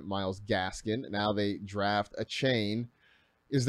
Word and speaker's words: Achane: But as far as we know, it's Devon Achane is how you pronounce it.